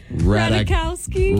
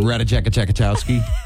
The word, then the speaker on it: Ratajkowski: Gosh, the rumors still flying around about him and Emily Ratajkowski. – Shut up. You did not just say that. – Ratajkowski. Ratajkowski. That's how I say it. That's how she prefers I say her name.